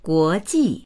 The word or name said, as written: guo2ji4